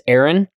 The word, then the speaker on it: Arran: So we pronounce this Aaron, A-R-R-A-N, Aaron, yep.